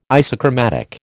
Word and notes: isochromatic: American English

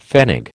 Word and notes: pfennig: American English

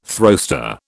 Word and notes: throwster: British English

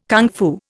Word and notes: kung fu: Indian

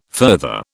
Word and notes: further: British English

